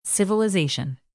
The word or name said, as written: civilization